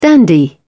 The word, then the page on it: dandy: British English